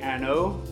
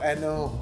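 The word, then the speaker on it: An Oa: Ardbeg Anno. – Oh, Anno. I'm glad you said it because I always mispronounce it.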